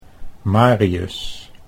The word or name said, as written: Marius